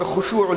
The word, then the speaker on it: خشوع: حلوے والی ہا کے بعد خا، الف با تا فا جیم ہا خا، خا شین عین واو، یا اس کا دوسرا تلفظ جو قرآن میں آیا، الف خا شین واو عین، خشوع، خشوع، خا شین واو عین، خشوع.